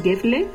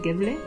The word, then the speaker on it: Gävle: pero creo que es Gävle, se escribe como Gafle a con diéresis, pero creo que es Gävle,